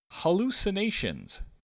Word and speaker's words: hallucinations: Next is hallucinations. Hallucinations has a schwa sound at the beginning and a long U, a long A, and the shun sound, which you are probably familiar with if you've heard any of countless different words such as information or education.